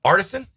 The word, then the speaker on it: artisan: Paninis. Artesian. Artisan? – Yeah, but that's okay.